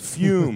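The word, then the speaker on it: Füm: Pronounced Fume, spelled F-U-M with those cool Motley Crue umlauts.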